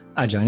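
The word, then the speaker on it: Aja: a tutaj jest Adja Aha, jak to się wymawia? Jezus Maria, nie, nie wiem. – Adzia, nie? Tak.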